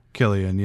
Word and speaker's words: Cillian: Is that how you pronounce his first name? Cillian. – Cillian, yeah.